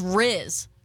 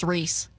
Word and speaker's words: Rhys: It's spelled R-H-Y-S. – R-H-Y-S? – Yes, ma'am. – Reese? – Is that Reese? – I had a kid in my class whose name was spelled like that, and he said it was pronounced Reese.